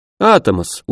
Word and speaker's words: Атомос: «Атомос, ударение на первый гласный.